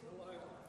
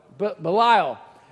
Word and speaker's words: Belial: Belial. Thank you. Belial.